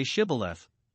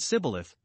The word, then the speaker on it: Shibboleth: If they took a man that they suspected to be an Ephraimite, but he denied it, they bade him say Shibboleth, but either he could not, as our translation reads it, or he did not heed, or frame, or direct himself, as some read, to pronounce it aright, but said Sibboleth, and so was known to be an Ephraimite, and was slain immediately.